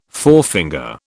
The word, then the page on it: forefinger: British English